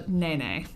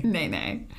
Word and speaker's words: nene: So this bird is called the nene. – Nene. – Nene. – Like to dance. Now watch me whip. Now watch me nene. – I was actually thinking it's spelled like Nene from The Real Housewives of Atlanta. – Oh. – So I was calling it the Nene goose. I'm glad I looked up the pronunciation.